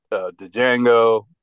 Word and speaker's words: Django: They've worked together on many big movies, Pulp Fiction, Jackie Brown, Django, The D is Silent.